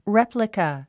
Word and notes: replica: réplikə; レェプリカ

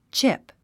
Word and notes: chip: tʃíp; チップ

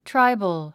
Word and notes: tribal: tráibəl; トライボゥ

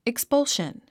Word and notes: expulsion: ikspʌ’lʃən; イクスポウション

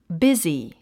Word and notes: busy: bízi; ビズィ

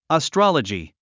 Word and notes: astrology: əstrɑ’lədʒi; アストォロジィ

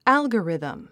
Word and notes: algorithm: ǽlgərìðm; アルゴリズム

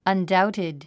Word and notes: undoubted: ʌndáutid; アンダウティド

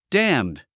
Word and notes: damned: dǽmd; ダァムド

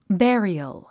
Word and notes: burial: bériəl; ベリヨォ

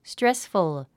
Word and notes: stressful: strésfəl; ストレスフゥ

